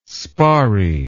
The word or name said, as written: sparry